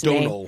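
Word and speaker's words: Domhnall: I never can pronounce this guy's Donal. – Name. Thank you, Donal Gleeson.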